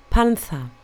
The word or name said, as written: panther